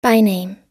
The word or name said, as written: byname